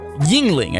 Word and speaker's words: Yuengling: Yingling, I say. Yingling. Spell it Yuengling.